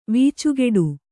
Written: ವೀಚುಗೆಡು

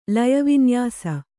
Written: ಲಯವಿನ್ಯಾಸ

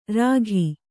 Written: ರೇಗು

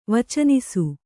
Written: ವಚನಿಸು

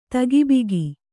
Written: ತಗಿಬಿಗಿ

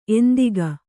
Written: ಎಂತಕ್ಕೆ

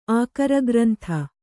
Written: ಆಕರಗ್ರಂಥ

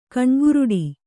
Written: ಕಣ್ಗುರುಡ

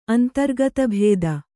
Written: ಅಂತರ್ಗತಭೇದ

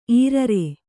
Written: ಈರರೆ

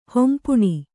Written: ಹೊಂಪುಣಿ

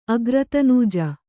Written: ಅಗ್ರತನೂಜ

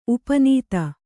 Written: ಉಪನೀತ

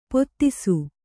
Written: ಪೊದೆಸು

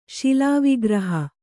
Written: ಶಿಲಾವಿಗ್ರಹ

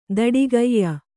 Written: ದಡಿಗೈಯ

